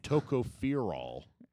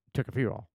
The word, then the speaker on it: tocopherol: And tocopherol. – You're saying it wrong. It's tocopherol.